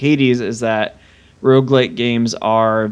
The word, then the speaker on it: Hades: You did say Hades.